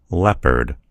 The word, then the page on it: leopard: American English